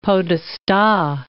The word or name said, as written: podesta